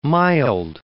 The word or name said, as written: mild